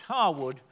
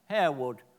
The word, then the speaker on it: Harewood: Thus, in a minor snobbery, Harwood, the home of the Lassells family and their earldom, is pronounced Harwood, whereas the village of Harewood, its location near Leeds, is pronounced as it's spelt, Harewood.